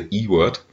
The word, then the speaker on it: Ewert: Ewert, also er sagt, er spricht sich normalerweise E-Word. – E-Word, ja, genau. Okay. So sprechen die Amerikaner das aus. Also nicht E-Word oder so ähnlich, sondern E-Word.